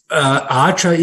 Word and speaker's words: Archer: Und was ist das für ein Auftrag mit diesen 100 Maschinen United Airlines, der EVTOL von Eicher? Wahrscheinlich wird es anders ausgesprochen, wahrscheinlich Englisch. – Archer ist ein Hersteller von Passagierdrohnen, ein neuer Player am Markt, der mit United Airlines eine Kooperation eingegangen hat.